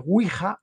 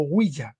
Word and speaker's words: Ouija: O bien en italiano, por ejemplo, se llama el tablerone, pero en castellano, en español, se llama Ouija o Ouija, como pronuncian algunos, cuyas partículas derivan de el sí en francés y el sí en alemán, Ouija.